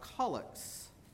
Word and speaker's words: collect: And the word is pronounced colic, even though when you see it printed in your program, as you do fairly close to the top of the page, on the first page of your program, it looks like collect.